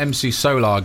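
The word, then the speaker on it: MC Solaar: How about MC Solar, Gangster Moderne? Have I pronounced that properly? – You have.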